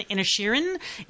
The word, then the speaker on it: Inisherin: I only went with Irish pronunciations and the internet pronunciations, which would have been Inisherin, an E instead of an A, and it could be the dialect of over there in County Galway.